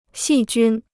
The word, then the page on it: 细菌: xì jūn